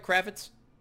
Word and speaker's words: Kravetz: Last week's winner, Howard Kravetz, did I pronounce that right, Kravetz? – You did. – Okay, like Kravetz. Lenny. – Exactly. – Like Lenny. – Different spelling.